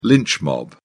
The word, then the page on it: lynch mob: British English